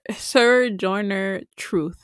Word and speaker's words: Sojourner Truth: How do you say her name? – Sojourner Truth.